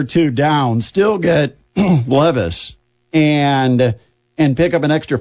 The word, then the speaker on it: Levis: If Levis, well, I believe it's pronounced Levis. – Yes. – I mispronounced it as Levis last week, and so I'll apologize.